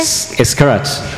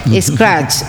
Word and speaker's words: Scratch: yo no sé pronunciar eso, ¿qué? – Scratch. – Scratch, ah, bueno, muy bien.